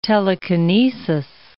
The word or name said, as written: telekinesis